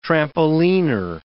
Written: trampoliner

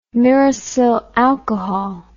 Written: myricyl alcohol

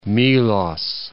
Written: Melos

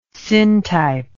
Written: syntype

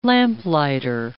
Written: lamplighter

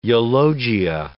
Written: eulogia

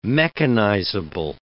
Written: mechanizable

